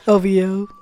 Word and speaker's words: OVO: Oh, you're saying it letter by letter. O-V-O. – That's how you pronounce from what I remember, that's how everybody pronounces it.